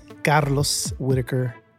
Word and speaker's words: Carlos Whittaker: I am Carlos Whittaker.